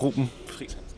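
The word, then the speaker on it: Reuben Friis: Ja, og så Oscar, du har et EU i dit navn, men det udtales Ro. Eller hvordan? – Det gør det, Ruben Friis.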